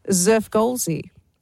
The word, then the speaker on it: Xfrgolszzzxy: Well, it is spelt X-F-R-G-O-L-S-Z-Z-Z-X-Y. She says it's pronounced Zerfgolzy.